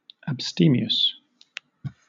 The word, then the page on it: abstemious: British English